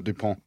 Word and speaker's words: Dupont: DuPont.